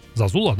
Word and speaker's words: Зазула: Скорее всего, пересечение действительно было, потому что, собственно говоря, как бы и продюсер Джон Зазула, да? Правильно сказал? – Да. – Зазула.